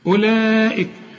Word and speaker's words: أولئك: أولئك، اسمع، كل واو عليها الخمسة الدوران دي تلغى في النطق، تك ولا تنطق، ما تنطقهاش. أولئك على طول، ما تقولش أولئك، ثلاث حروف عليهم الدوران ده، بيسموهم العلماء حروف علة، الواو الياء الألف.